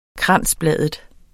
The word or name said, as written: kransbladet